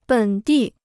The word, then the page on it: 本地: běn dì